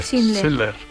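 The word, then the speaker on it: Schindler: Este violín que está llorando ahora, así de fondo, eh, forma parte de la banda sonora de la lista de, de Cylinder, ¿se mm. pronuncia? Sindler. – Sindler.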